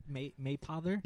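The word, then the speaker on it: Mapother: Is that how you say Maypother? – Yeah, we figured that out. Maypother. It rhymes with Maybother.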